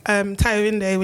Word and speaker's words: Tayorinde: My IG is Tayorinde, which is spelled T-A-I, wow, can't spell my name. T-A-Y-O-R-I-N-D-E.